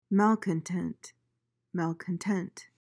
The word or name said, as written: malcontent